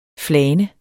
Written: flane

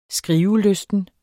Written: skrivelysten

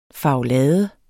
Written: farvelade

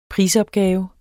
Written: prisopgave

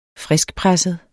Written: friskpresset

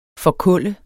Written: forkulle

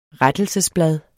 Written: RETTELSESBLADE

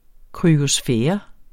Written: kryosfære

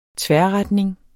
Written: tværretning

